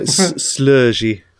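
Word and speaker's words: Sloggi: Sorry, how do we pronounce that? You're our pronunciation expert for Scandinavian sounding brands. – It sounds like Slurgy.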